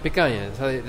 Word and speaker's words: picanha: Picanha. That's how they pronounce it there.